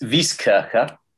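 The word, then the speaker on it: Wieskirche: Is that Vieskirch? Is that how you spell it? – This is Vieskirche.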